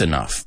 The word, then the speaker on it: enough: Just not fast enough, pero no lo suficientemente rápido. Okay? Careful with this. This enough, primero, se pronuncia esa O-U-G-H en este caso, como f, right? Como cough, tos. Enough.